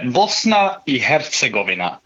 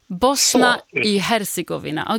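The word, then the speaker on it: Bosna i Hercegovina: Men ska man uttala det på bosniska så är det Bosna i Herzegovina. – Bosna i Herzegovina.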